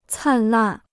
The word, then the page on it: 灿烂: càn làn